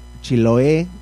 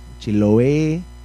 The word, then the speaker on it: Chiloé: Y bueno, pues un saludo también para Chasqui, que nos dice que Chiloé, con acento en la E, Chiloé, esperemos que esté bien pronunciado.